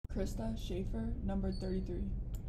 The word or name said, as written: Krista Sheaffer